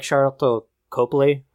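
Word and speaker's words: Sharlto Copley: So, I liked Chappie, but I also like Charlotte Copley.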